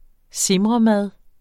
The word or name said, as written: simremad